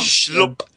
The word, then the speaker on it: Schlupp: He's put about eight U's in the word Schlupp, which is exactly that is how it should be pronounced. – Schlupp.